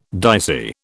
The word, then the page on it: dicey: British English